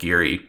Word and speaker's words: Girih: He also plays in a post metal band called Geary. I think that's how it's pronounced. It's spelled G-I-R-I-H, and his live board is it's a thing to behold.